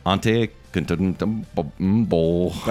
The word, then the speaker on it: Antetokounmpo: Unfortunately, they cannot compete with Giannis, Antetokounmpo. – That's exactly how you pronounce it.